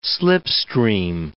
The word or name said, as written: slipstream